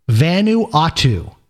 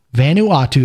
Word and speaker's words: Vanuatu: So here's what we have today there's a small island off the coast of Australia, which is pronounced Vanuatu. Vanuatu.